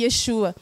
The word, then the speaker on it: Yeshua: Porque Jesus em hebraico, gente, é Yeshua, né? A gente pronuncia um pouco assim, Yeshua. E tem muitos Yeshua lá, lá em Israel.